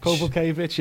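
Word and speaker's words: Kowalkiewicz: Kovalkovic? – Yeah, Kovalkovic. – Kovalkovic, yeah. I nailed that. Listen. – Or Kovalkovic. – Kovalkovic, yeah, for the American pronunciation.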